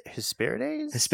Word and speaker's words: Hesperides: Hesperides. – So the trick with Greek is that you pronounce like every vowel. Like every vowel gets its time in the sun.